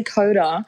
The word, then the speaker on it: Kota: Do you pronounce the T in your name? – Well, do I? No, I don't actually. I say Kota. – Yeah, you pronounce it with a D.